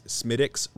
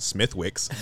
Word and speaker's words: Smithwick's: Another one is Smithwick's Red Ale. It's, if you're an American, pronounce Smithwick's, but in Dublin they pronounce it Smithwick's.